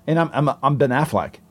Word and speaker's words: Affleck: Is a fleck a flack a fluke? Wait, how, how how do you say it? I say Affleck. – Ben Affleck. Yeah. – all right, like, like the duck.